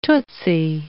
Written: tootsie

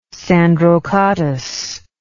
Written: sandrocottus

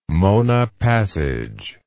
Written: Mona Passage

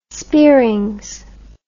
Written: speerings

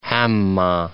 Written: Hamah

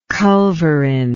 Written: culverin

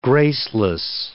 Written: graceless

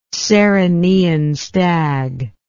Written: Cerynean stag

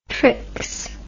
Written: trix

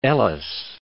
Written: Gilbert and Ellice

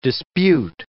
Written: dispute

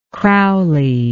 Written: crowley

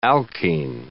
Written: alkene

